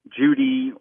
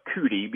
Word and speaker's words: Judi: There's also Mount Judi, or over there they call it Kuti because they can't pronounce a J. They pronounce it with a C or a K.